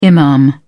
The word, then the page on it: imam: British English